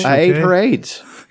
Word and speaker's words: AYDS: That's what it was at the beginning, was AYDS. Okay. But it's still pronounced AIDS.